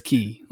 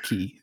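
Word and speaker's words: Cay: It's spelled K, but everybody says Key. Like even the Disney travel agents say Key.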